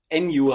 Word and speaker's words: Enjo: Äh, NU heißt das. – Wie schreibt man das? – E-N-J-O e wie Enjoy, eigentlich ohne Y am Ende.